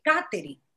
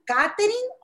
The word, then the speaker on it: Katherine: But I recently thought, my mother has never in her life or in my life called me Katherine. She's always pronounced it Katherine. And since she is the person who gave me my name, is my name Katherine or is it Katherine? And I get it. Some people will go, "Aren't they the same name?" Yes, but the pronunciation is different.